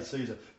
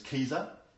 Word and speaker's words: Caesar: You know, it's rather interesting, we say Caesar. You know, the original pronunciation was Caesar. It doesn't sound right, does it? Hail Caesar! No, it doesn't sound right. But it's the same as Cicero, you know. It was Cicero, a hard C.